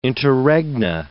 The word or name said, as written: interregna